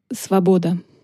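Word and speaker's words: svoboda (свобода): Il se dit « svoboda ».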